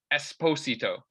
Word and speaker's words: Esposito: because his last name is pronounced Esposito. Like the syllable is like different.